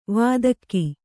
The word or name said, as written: ವೇದಕ